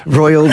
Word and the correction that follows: Roald: Roald Dahl. Roiled, like Roald Dahl.